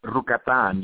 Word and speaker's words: Rukatan: No, you said it good, man. It's Rukatan. You got to roll that R.